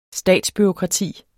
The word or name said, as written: statsbureaukrati